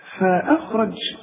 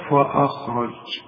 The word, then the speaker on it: فأخرج: فأخرج أنا قلتها صحيحة طبعا، لكن اسمع بقى النطق الخطأ اللي الناس كتير بتقع فيه، تقول لك فأخرج. طبعا انت خدت الهمزة، انما احنا ما ركزناش على الفه دي، ما نقولش بقى فا.